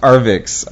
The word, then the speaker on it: Arvixe: Anyway, it's Arvix, or it's pronounced Arvix. I always say it wrong.